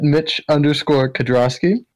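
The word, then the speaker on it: Mitch Kedrosky: Instagram at Mitch_Kedrosky. That's K-E-D as in David, R-O-S as in Sam, K-Y.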